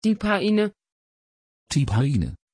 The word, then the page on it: tiphaine: Dutch